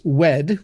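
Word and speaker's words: Wehde: Andrew, I believe it's pronounced Weedy. It's W-E-H-D-E.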